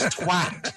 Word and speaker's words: twat: It is twat. It rhymes twat. – With hat. It rhymes with cat.